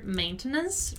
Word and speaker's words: maintenance: We've already talked about the pronunciation of the word maintenance, right? So just remember that the stressed syllable is the syllable main. So maintenance.